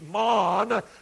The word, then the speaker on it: mon: and the word pronounced by the Scottish mon simply is their word for man.